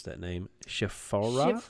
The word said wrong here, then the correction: Shiphrah: Shiphrah. Yeah, huh?